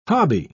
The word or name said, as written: hobby